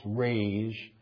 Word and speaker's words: raze: Verse 7, the word raz, sometimes the word raise, sometimes spelled R-A-Z-E, it's pronounced raise.